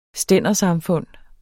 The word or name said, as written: stændersamfund